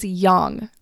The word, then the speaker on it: yang: Well, the truth is, my friend, Americans say yang funny. I remember in one of my first classes, Dr. Chang, she was like, what does yang? Why are you all saying yang? It's yang. Yang, Y-A-N-G, is pronounced yang.